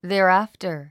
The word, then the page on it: thereafter: ðè ə rǽfə r; ゼラアフタァ